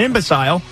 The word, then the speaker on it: imbecile: He was an imbecile. – Imbecile, you mean? – Imbecile. – Is that the right word? – I thought it was imbecile. – No, well, I mean, it's spelled I-M-B-E-C-I-L-E, so imbecile.